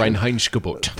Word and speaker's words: Reinheitsgebot: Reinheitsgebot.